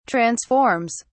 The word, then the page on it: transforms: British English